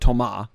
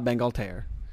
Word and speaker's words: Thomas Bangalter: But the tall silver one, his name in real life is, it's spelled like Thomas, but you pronounce it Thomas. And his last name is Bangalter, or Bangalter. And they're French, so that's why it's Thomas Bangalter.